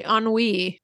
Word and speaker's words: ennui: Yeah, it's the ennui. Did I say it right? – Mm-hmm, you did.